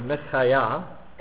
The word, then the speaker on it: Micaiah: The name of the prophet Micah, or Micaiah, as it's probably pronounced in Hebrew, means who is like Yahweh, or God.